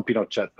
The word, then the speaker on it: Pinochet: They call him Pinochet, though, because in Spanish, that's how you say it.